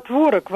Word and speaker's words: творог: Режет слух, видите ли, ударение на первом слоге. А мне режет слух ударение на втором слоге. Особенно если сказать «пирог с творогом», это уж совсем не звучит. «Пирог с творогом», наверное. Хотя я знаю, что оба ударения допустимы.